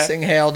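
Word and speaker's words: cinghiale: Sing Haley. – Ching Hail. Okay. – Sing Hail, not Ching.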